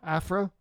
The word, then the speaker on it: Aphra: Aphra.